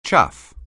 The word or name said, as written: chaff